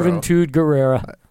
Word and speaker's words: Juventud Guerrero: Juventud Guerrero. – I'm going to congratulate you on pronouncing that name because I saw it and I was like, I can't even.